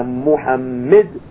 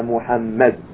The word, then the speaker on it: Muhammad: We shouldn't say Ashhadu anna Muhammad. We should say Ashhadu anna Muhammad.